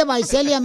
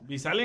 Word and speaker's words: Visalia: Se dice Vaiselia, menso. – ¿Eh? – no se dice Visalia. – Visalia en español. – en inglés.